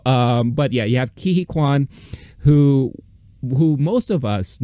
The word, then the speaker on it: Ke Huy Quan: Uh, did I say his name right? – Ke Huy Quan. Yeah.